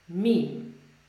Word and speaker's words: meme: In Polish, we say mem, but in English, we say meme. So we say, um, we pronounce it as meme.